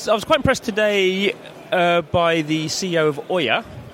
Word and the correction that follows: Ouya: Ouya. – Ouya. Is this an on-running joke, is it? – No, it's just how you pronounce it.